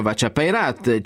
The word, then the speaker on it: Vescia Peirat: Per esempio c'è Joe Mandelnickel, che naturalmente i milanesi pronunceranno come Joe Mandelnickel, c'è Vescia Peirat, ma che si legge in meneghino Vacia Peirat, c'è anche una Kerhampan, che naturalmente è Carampana.